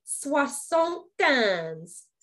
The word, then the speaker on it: soixante-quinze: It is also called a 75 cocktail, or in French, simply a soixante-quinze, 75. And I did take French, so I did say that right.